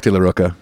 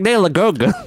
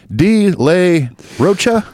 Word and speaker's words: De La Rocha: Zach De La Rocha, yeah.